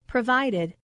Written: provided

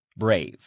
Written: brave

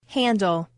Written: handle

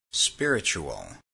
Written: spiritual

